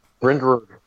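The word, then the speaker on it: Bryndrwg: Her Twitter at Bryn Grug. – spell that out. – B-R-W-G. – It's B-R-Y-N-D-R-W-G. Sorry. We're all talking at the same time. – Brindrug. She's my friend. That's how you say it.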